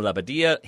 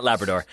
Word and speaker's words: Labbadia: However, Hertha coach Bruno Labbadia hinted that's how you pronounce it. No one should be pronouncing it the other way. – Nah, not Labrador.